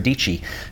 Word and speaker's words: Dietschy: Sarah Deechy. She says it rhymes with peachy, but more importantly, Sarah is a very talented photographer and filmmaker who lives in Nashville, Tennessee.